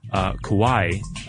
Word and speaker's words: kawaii: She says, "Hey guys, I just wanted to let you know that in Japanese, kawaii, K-A-W-A-I-I, well, that's the, you know, the English version of the kanji, cute is pronounced ka, like the kaing of a crow. So ka. Ka, like the cawing of a crow. Wa is in water, and I as in the letter E. So kawaii. – Kawaii. – Like Hawaii.